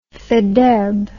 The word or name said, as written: faidherb